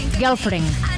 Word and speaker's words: Girlfriend: Entre ellos uno de estos que es Girlfriend. – Correcto. – No sé si lo he pronunciado bien. – Sí, lo has pronunciado de maravilla.